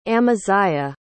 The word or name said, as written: amaziah